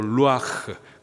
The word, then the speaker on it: 루아흐: 여기서 생기, 히브리식 발음으로 루아흐, 그 뜻은 생기, 호흡 하는 호흡, 그리고 바람, 바람, 그리고 성령이라는 뜻을 갖고 있어요.